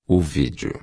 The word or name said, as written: vídeo